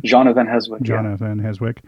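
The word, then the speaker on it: Jeanne van Heeswijk: And I think you said Jenny van Heswijk. Did I say that right? – Jana van Heswijk.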